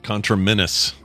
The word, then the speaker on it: conterminous: Ooh, I'm putting the emphasis on the wrong syllable. conterminous. – Okay. I have a pronunciation of it right here. – Conterminous. – Yeah, you're right. Conterminous.